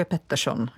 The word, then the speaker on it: Pettersson: To jenom, když máš kombinaci r a s za sebou, tak tam jako to trošku je Pettersson, jo?